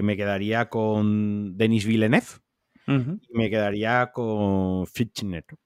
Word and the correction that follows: Fincher: Así a tres a Fincher. nunca lo pronuncio bien. Vale. David, David yo lo llamo David. – David, Fincher.